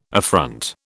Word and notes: affront: British English

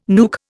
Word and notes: nuke: Indian

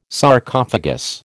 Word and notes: Sarcophagus: American English

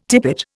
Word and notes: tippet: Indian

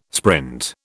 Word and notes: sprint: British English

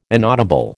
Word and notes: inaudible: American English

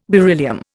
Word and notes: beryllium: Indian

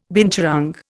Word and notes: binturong: Indian

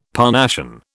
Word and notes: Parnassian: British English

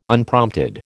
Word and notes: Unprompted: American English

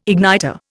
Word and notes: igniter: Indian

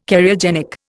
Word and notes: Cariogenic: Indian

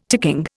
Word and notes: ticking: Indian

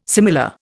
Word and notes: similar: Indian

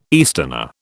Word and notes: easterner: British English

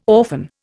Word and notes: orphan: Indian

